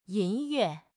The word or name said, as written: yin1 yue4